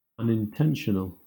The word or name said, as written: unintentional